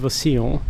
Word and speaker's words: Roussillon: Your current role is playing, I'm not sure I get the pronunciation right, the Countess of Roussillon. – Yes.